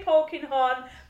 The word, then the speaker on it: Polkinghorne: Claire Polkinghorne. – I was going to say Polkinghorne, but I was just a little bit apprehensive. – Yeah, it is.